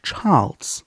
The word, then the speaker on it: Chaarlz: Ähm, Charles. C-H-A-A-R-L-Z. Charles.